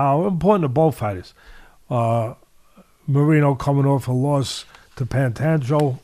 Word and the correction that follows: Pantoja: Am I saying it right? He lost his title to Pantoja.